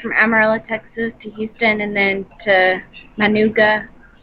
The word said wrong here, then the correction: Managua: Managua? – Managua. – Managua. Okay.